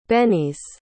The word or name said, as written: bennies